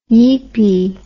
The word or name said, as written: yeepie